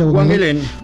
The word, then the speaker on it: Huanguelén: Huanguelén.